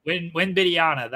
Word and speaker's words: Wembanyama: We're gonna get Wanabayu, and I think it's how you say his name. I should probably learn if he's gonna be Victor Wembanyama. – Wembanyama, that's who. That's how you say it.